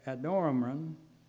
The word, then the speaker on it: Adoniram: Amram, Adoniram, that's how you pronounce that, was in charge of the labor force.